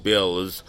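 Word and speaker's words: Bălți: It's spelled B-A-L-T-I. But they pronounce it Bills.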